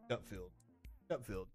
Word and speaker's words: Gutfeld: Gutfield. Yeah, I said it right.